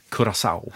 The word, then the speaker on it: Curaçao: Jag har fått stöd av producenten att man ska tydligen uttala det Curaçao.